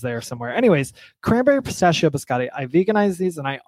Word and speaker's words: biscotti: Biscotti.